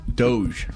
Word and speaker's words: Doge: It is International Doja Day. You know, like the Doja coin? – Doge. – Doge. Yeah, D-O-H-J is how it's pronounced. Doge. Yeah, there you go. – D-O-G-E, right? Is how it's spelled, though? – D-O-G-E.